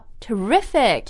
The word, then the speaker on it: terrific: Okay, so terrible 是很不好，但 today we're talking about terrific. – Terrific, yeah, so the stress is on the second syllable, terrific.